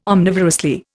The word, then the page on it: omnivorously: Indian